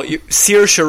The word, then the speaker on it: Saoirse: Also, Saoirse Ronan is how you pronounce it.